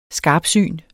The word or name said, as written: skarpsyn